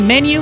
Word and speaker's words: menu: Are you trying to say menu, honey? – Menu is the correct pronunciation. It's a French word. Menu.